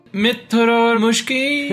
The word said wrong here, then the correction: Mrityor: No, it is Mrityor Mukshya. Mrityor. Yes. Mrityor.